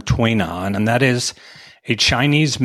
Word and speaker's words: Tui Na: And for anybody who cares about that, that is spelled T-U-I-N-A. And it is pronounced Tui Na.